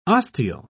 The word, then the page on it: osteal: British English